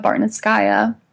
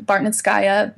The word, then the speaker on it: Bartnitskaia: It's just under my name, Luba Bartnitskaya. – Could you spell that for our listeners? – So it's L-U-B-A, and my last name is Bartnitskaya, B-A-R-T-N-I-T-S-K-A-I-A.